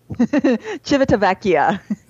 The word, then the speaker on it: Civitavecchia: How do you pronounce that city again? – Civitavecchia.